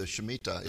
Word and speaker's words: Shemitah: And now you have a new book out called The Mystery of the Shemitah. Is that the correct way to pronounce that? – Yes, you did it perfectly.